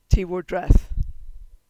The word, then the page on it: Ti war Dreth: Cornish